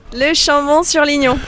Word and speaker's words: Le Chambon-sur-Lignon: Le Chambon-sur-Lignon.